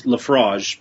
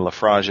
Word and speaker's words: Laphroaig: L-A-P-H-R-O-A-I-G. – Yeah. – I've got Wikipedia telling me, with a little audio clip, it's pronounced Laphroaig. – Laphroaig. All right, so I was saying it wrong.